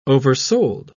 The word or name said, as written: oversold